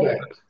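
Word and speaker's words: Åberg: And Ludwig Aberg, is how do you pronounce Aberg? I keep calling him, Oberg, Ludwig Oberg. – Aberg.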